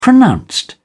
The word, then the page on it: pronounced: British English